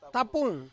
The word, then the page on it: tapung: Ansus